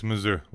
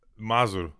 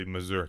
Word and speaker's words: Mazur: It's Mazur. Wait a minute. It's been such a long time since I've said it properly. Mazur. That's the proper accent. – Mazur. Okay. – You know, I'll just say Mazur because I've lived here for so long that. – Yeah, I just got used to saying my own name wrong.